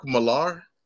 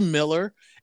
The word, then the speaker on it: Millar: It's actually Miller. Phonetically, it looks like Millar. And yeah, a lot of people made that mistake. But Mark Miller. He's written quite a few different comic book That's really Miller? – Yeah. He's Scottish, so it's pronounced Miller. It looks like Millar, but it's Miller.